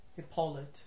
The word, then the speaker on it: Hipolit: Hippolyte. Hippolyte. – Can you spell that, please? – H-I-P-O-L-I-T. – Hippolyte.